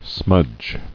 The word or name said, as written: Smudging